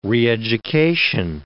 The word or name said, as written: reeducation